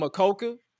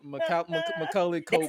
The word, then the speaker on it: Macaulay Culkin: I was about to call him Makoka. Like Makoka. I'm about to say Makoke, but I'm like, let me get you to pronounce it. Macaulay Culkin.